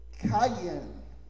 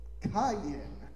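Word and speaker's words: Qayin: And Cain is spelled, the Hebrew word, if you spelled it in English, you know, not directly translated, it's Q-A-Y-I-N. And it's got a real funny pronunciation. It's Cayenne. Cayenne is how they pronounce it.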